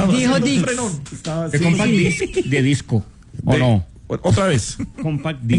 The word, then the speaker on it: Disc: Discs. En Paraguay. – No, espera. D-I-S-C. – Claro, de disco. – Disc. – Disc. – Lo digo bien. – Muy bien, a ver.